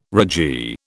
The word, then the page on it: ragee: British English